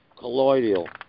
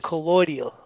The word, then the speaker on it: colloidal: I think it's collodial or collateral. Let me see how they pronounce this. Let me look this word up. – Colloidal. – Colloidal? Is that how they say? Okay, yes. Colloidal.